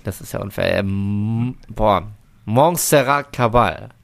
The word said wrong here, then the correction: Caballé: Nein. Caballé. Caballé.